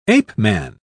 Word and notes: ape-man: British English

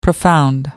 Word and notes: profound: American English

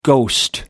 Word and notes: ghostwrite: American English

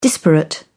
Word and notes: disparate: British English

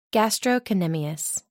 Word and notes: gastrocnemius: American English

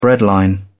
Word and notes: breadline: British English

